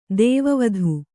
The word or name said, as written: ದೈವವಿದೆ